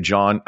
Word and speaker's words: Jawn: Oh, and John's. Pizzeria John. I think that's how you pronounce it. It's spelled J-A-W-N.